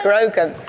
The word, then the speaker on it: Grogu: Grogu.